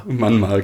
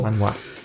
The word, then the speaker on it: Manhwa: Manhwa, genau. – Manhwa. – Ich dachte, irgendwas mit der ersten Silbe stimmt dabei nicht, aber es ist die letzte Silbe.